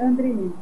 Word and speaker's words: André: O sobrenome é André. É simples, é como se escreve mesmo.